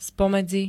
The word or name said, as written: spomedzi